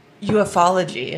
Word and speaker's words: ufology: There's also UFOology. – Oh. I think it's... Ufology is how you say it.